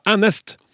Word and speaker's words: enest: I min dialekt, ranværing, har vi uttrykket enest, eh, som uttales med æ, altså enest.